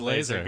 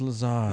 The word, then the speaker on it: Lazar: He's the guy that talked about Lazar, but it's spelled laser, and he's like a cop with a laser gun. – Yeah. No, it's not laser, it's Lazar.